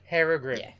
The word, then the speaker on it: Herugrim: Herugrim.